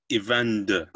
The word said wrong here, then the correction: Eyvindur: Is that right? – Eyvindr. – Eyvindr. Cool. Got it.